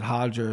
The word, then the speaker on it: Roger: I love that his name's Roger, and they pronounce it Hodger as well.